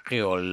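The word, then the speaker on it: Kreyòl: Créole, là, écrit, je le prononce à K-R-E-Y-O-L. Je prononce créole, car c'est en français, je ne parle pas les noms. Moi, je prononce VOA créole. Bis.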